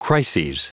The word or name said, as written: crises